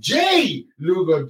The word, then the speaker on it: Jae Lugo: Is it Jace? – Jay Lugo.